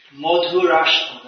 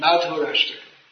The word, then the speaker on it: Madhurashtaka: Madhurashtaka, that's Bengali pronunciation. Madhurashtaka.